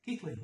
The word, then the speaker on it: Keighley: Is that how you say that? – Keighley, yeah. – Even though it doesn't spell that.